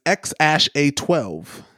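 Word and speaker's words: X Æ A-12: It's apparently the way that you pronounce this is X-A12.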